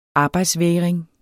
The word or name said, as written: arbejdsvægring